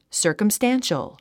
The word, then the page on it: circumstantial: sə̀ː r kəmstǽnʃəl; サーカムスタンショゥ